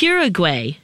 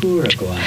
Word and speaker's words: Uruguay: The poet camper, a South American goal, to hike Uruguay.